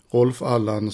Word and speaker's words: Rolf Allan: För det uttalas ju så. Rolf Allan som jobbade med Galenskaparna och var deras, vad ska man säga, deras George Martin.